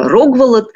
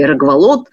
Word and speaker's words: Рогволод: И вот там, видимо, кто-то из наследников еще наместников самого Рюрика, некто Рогволод или Рогволод, по-разному это имя произносят, вот правит.